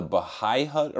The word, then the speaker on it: Bahi: We went to this bar called the Bahi Hut. I think they call it the Bahai Hut around here. It's spelled B-A-H-I, but it's like a real 1950s tiki bar, you know, with like stones on the walls and like masks hanging everywhere and like.